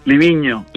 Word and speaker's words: Livigno: Ho pronuncia bé, Livigno? – Livigno, Livigno, sí, sí, sí.